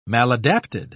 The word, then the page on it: maladapted: British English